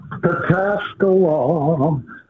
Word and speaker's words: Pataskala: Tell me how to say this. – Pataskala.